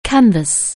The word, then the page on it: canvass: anglais